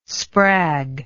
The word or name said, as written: sprag